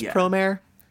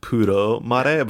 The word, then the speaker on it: Promare: I think it's just Promare, even though like the Japanese way it would be like Puro Mare, but like I think it's just Promare. That's what I tend to hear.